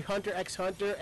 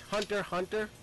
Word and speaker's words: Hunter x Hunter: Like, I used to say Hunter x Hunter, and ActF keeps screaming at me, it's Hunter Hunter.